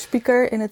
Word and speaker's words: spijker: Dus spijker is speaker, zo spreek je dat uit in Twente.